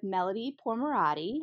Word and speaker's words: Melody Pourmoradi: I'm here with today with Melody Pourmoradi.